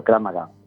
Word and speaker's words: Krav Maga: Krav Maga, o sea, lo he dicho, lo he pronunciado bien, primero de todo. Primero tu nombre, perfecto. Sí, y el Krav Maga. – También, perfecto, Krav Maga, sí.